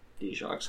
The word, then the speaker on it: Dijak: I told her that D-Jock's how we've always pronounced it, and they were pronouncing it Dijak on the, okay.